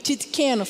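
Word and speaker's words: Tsidkenu: Jeová Titqueno. Acho que é isso. É difícil falar. Titqueno. Jeová, é que é muito, a pronúncia é muito difícil. É, para você que quer entender, é T-E-S-D-I-Q-E-N-U. Titqueno, fala Titqueno.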